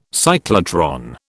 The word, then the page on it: cyclotron: British English